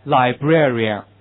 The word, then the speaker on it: librarian: 你们没有这么有自信？OK， OK， now please read after me。OK，这首先重音在后面啊，librarian 这单词怎么读？librarian。